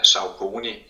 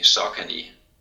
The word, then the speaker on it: Saucony: de fleste i Danmark udtaler det uh, øh, Saucony, men uh, den korrekte måde vil være at sige Sokani.